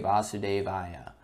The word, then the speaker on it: Vasudeva: Indeed, the very first verse of the Srimad Bhagavatam begins Om Namo Bhagavate Vasudevaya. In this invocation, the Sanskrit word Vasudev refers to Krishna. Vasudeva with a short a is Krishna's father.